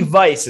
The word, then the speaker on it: Weiss: They have a great selection in Miami Vice, it's called. You spell it like Miami Weiss.